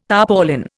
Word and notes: tarpaulin: Indian

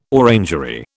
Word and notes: orangery: British English

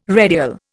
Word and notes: redial: Indian

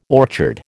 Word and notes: orchard: American English

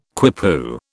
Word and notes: quipu: British English

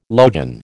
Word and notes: logion: American English

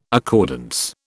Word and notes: accordance: British English

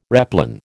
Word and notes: replan: American English

